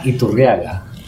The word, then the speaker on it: Iturriaga: Yvan Iturriaga.